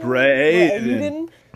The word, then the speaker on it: Braeden: Braden. He's got a very like erudite voice. And... – yeah, B-R-A-E-D-E-N.